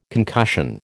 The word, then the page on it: concussion: American English